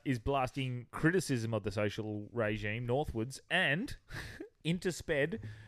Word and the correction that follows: interspersed: Interpursed? – It's not pronounced sped, it's retard. – You only get three of those. – Yeah, it says interspersed, so interwoven with K-pop music.